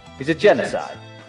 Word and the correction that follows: Genasi: A genocide? – Did I say that right? – Is that mass? – Genasi. – Genasi.